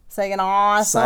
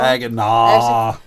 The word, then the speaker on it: Saginaw: Sagan Awesome. I said it wrong. – Saginaw? – Sagan Awesome. – Saginaw.